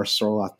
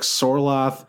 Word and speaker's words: Sorloth: I just like Sorloth all, and I actually think it's pronounced Sorlap, but I don't like the way that sounds, so I'm going to keep saying Sorlap.